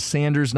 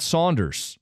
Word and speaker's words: Sanders: I've never heard Sanders pronounced Saunders until today. Another one says Sanders, not Saunders.